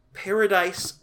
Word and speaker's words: Paradis: The only thing that's new as far as, like, notable credits people is Nicole Paradise Grindle. Which Paradise is spelled without an E, so it's like Paradis.